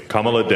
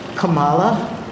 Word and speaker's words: Kamala: Ladies and gentlemen, the vice president-elect of the United States, Kamala Debbie Harris and Mr. Douglas Imhoff. – Harris chose Justice Sonia Sotomayor for the occasion, and Sotomayor responded in kind by mispronouncing Kamala. – I, Kamala Debbie Harris, do solemnly swear.